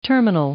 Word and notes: terminal: noun

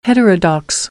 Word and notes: heterodox: American English; ɚ - əɹ

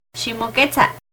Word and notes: Ximoquetza: Náhuatl

